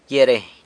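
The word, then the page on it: gyia reiny: San Lucas Quiaviní Zapotec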